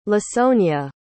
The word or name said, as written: lasonya